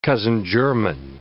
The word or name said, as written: cousin-german